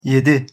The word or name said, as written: yedi